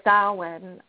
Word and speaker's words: Samhain: It goes way back before Christianity, and it's typically found its roots in what's called the Samhain. That's how you pronounce it, but it's spelled Samhain, if you want to look it up on the internet.